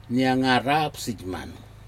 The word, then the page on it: niaga ra nupsijman: Aneityum